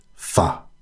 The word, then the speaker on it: pho: You pronounce it pho. It's fine. You can just do that.